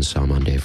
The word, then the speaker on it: Cymande: We're going to hear some Salmon Day with a message. I've been mispronouncing Salmon Day for a long time because I spell it C-Y-M-A-N-D-E, but no, it is pronounced Salmon Day.